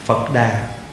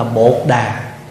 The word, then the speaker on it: Phật đà: chữ phật đà là phiên âm từ tiếng phạn là từ cái chữ buddha buddha người trung hoa âm là phật đà có khi mình đọc là bột đà